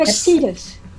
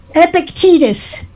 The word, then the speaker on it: Epictetus: Epictetus. – Say it again. – Epictetus. – Epictetus.